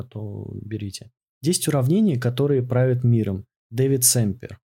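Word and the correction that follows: Sumpter: Санпер, точнее.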